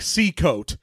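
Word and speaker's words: Sekot: There is a point where someone says, ah, like Seacoat. – Oh, okay. – And so I was like, all right, I'll say Seacoat.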